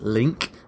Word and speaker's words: LingQ: So you mentioned LingQ, I think it's supposed to be pronounced Link, L-I-N-G-Q, Steve Kaufman's website, and there's ReadLang, which is also really great.